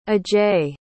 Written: ajay

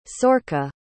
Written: Sorcha

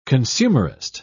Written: consumerist

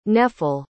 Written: nephele

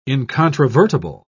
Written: incontrovertible

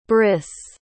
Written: brys

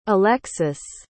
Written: elexus

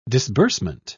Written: disbursement

